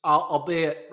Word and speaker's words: albeit: Is it albeit or is albite? How do you say that word? Albeit? – No, albeit? – You did it right. You did it. You did it right the first time.